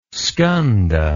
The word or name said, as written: skanda